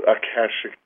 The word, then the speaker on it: Akashic: Well, I'd have to say that, you know, from what we've heard about the divine plan or the Akashic record, am I saying that right? – Mm-hmm.